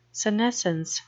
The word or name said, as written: senescence